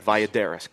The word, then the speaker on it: Valladares: And by Oscar, the manufacturer of the cigar is Oscar Valladares, or mm-hmm. Valladares, mm-hmm. Depending on how you want to... – Valladares. – Correct pronunciation.